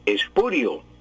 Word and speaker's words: espurio: Espurio, con I, con diptongo I, O, al final. Y pues no lleva tilde porque es palabra grave, pero antes hay un diptongo espurio. Espurio. Entonces no lleva tilde. Y se pronuncia pues en una sola sílaba las palabras graves. Espurio. Espurio, se pronuncia, tiene tres sílabas. Espurio, el acento es grave.